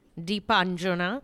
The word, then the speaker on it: Deepanjana: Because she's not a dip. She's Deepanjana.